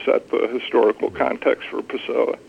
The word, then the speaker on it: Pessoa: We had Douchebag, and I make sure I'm saying this right, Pessoa. – Yes, Fernando Pessoa. – And Pessoa,